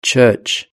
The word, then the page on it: church: British English